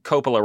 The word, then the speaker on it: Coppola: I keep pronouncing Coppola wrong. It's Coppola.